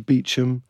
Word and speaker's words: Beauchamp: So Beaulieu became Beaulieu, Beauchamp became Beecham.